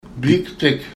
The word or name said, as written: biiǧtig